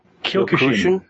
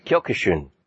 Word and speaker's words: Kyokushin: Kyokushin. – Kyokushin.